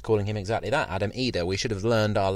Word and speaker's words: Idah: I should point out that I think we've all made the mistake already, haven't we? Idah. As we have learned today. Adam Idah is how it should be. I've got to get used to it not being Idah. It's spelled I-D-A-H, but we should be calling him Adam Idah,